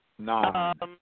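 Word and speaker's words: Nhan: But the security guard, how do you pronounce that name? Nan? – Yes. Nan. Yep. Like the Wrath of Khan.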